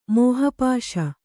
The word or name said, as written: ಮೋಹಪಾಶ